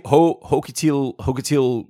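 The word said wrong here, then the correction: Xochitl: It's pronounced Sochi. – Okay, Sochi. I was so far off.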